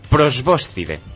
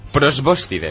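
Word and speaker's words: probóscide: Prosbóscide. – ¿Dónde está el acento? Para no cagarla como antes. – En la segunda O.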